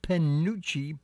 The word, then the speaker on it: penuche: Now, it's pronounced Panucci, P-E-N-U-C-H-E. Panucci, and it's a distinctive style of fudge.